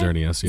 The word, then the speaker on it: Xerneas: The movie is related to Xerneas. Did I say that right? – Xerneas, yeah.